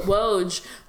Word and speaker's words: Woj: And then I'm gonna say his name wrong. A Woj bomb. – Oh, um, oh, no, no, you said it right.